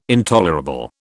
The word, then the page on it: intolerable: British English